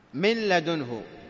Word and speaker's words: من لدنه: من لدنه، لاحظ من لدنه، تقرأ مل بالتشديد اللام كما هو عندكم، من لدنه. كيف ننطقها؟ من لدنه، نقول من لدنه، من لدنه، النطق يكون للام، مل من لدنه، واضح؟ يكون للام وتذهب هذه النون وتذهب صفتها.